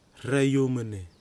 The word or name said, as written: Hrayumene